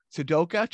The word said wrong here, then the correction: Sadako: channel. Did I butcher that too much? – Sadako. Sadako channel.